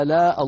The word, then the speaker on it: آلاء: فاذكروا آلاء الله في الآية 69 أيضا، آلاء الله، كلمة آلاء فيها مدان، هذا بدل بمقدار حركتين، لا هذا متصل بمقدار 5 حركات أو بمقدار 4 حركات، من قرأه 5 حركات فهو صحيح، ومن قرأه 4 حركات فهو صحيح أيضا، ونطقه هكذا.